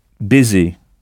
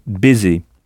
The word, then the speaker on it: busy: Alors, vous aviez peut-être appris avec Michel Thomas que « occupé » se dit « baiser ». Ça s'écrit « b-u-s-y », mais on prononce « baiser ». Donc, avec le son « b » au début.